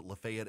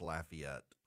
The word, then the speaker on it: Lafayette: Those of you in Georgia who pronounce it Lafayette, and I realize I got an affiliate in Lafayette, it's Lafayette.